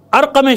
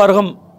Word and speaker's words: ارقم: آئیے، جب یہ ارقم شامی کو، جس کو ارغم اور بعض لوگ غین سے لکھ بھی دیتے ہیں کیونکہ تلفظ کیا، جب سنا ارغم ارغم تو نہیں، ارغم شامی لکھنا شروع کر دیا، نہیں ارقم نام ہے.